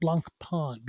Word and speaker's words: Blancpain: Okay, so next I have Blancpain. It's spelled blank pain, but it's pronounced Blancpain.